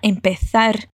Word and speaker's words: empezar: Mais uma vez, e atenção ao som do Z, como eles pronunciam na Espanha, com esse sotaque bem espanhol. Vai lá. Para empezar.